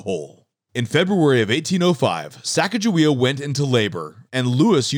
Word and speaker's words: Sacagawea: She's 6 months pregnant, and her name is Sacagawea. In some interpretations, her name is pronounced Sacagawea.